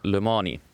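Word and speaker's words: Le Mans: aga äh, liigume edasi ja võtame ette teema number kolm ning püsime autospordi juures ja räägime Le Mansi, kas ma hääldasin õigesti? – no Le Mani.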